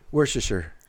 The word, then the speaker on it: Worcestershire: How do you pronounce this word? – Worcestershire. – Okay, so you can say it right. – Yeah. – Everyone else calls it, I've heard, Worcestershire. – Worcestershire, yes.